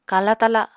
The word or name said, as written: kala-tala